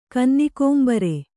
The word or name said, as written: ಕನ್ನಿಕೋಂಬರೆ